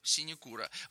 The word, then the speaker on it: синекура: А в слове синекура на у, синекура.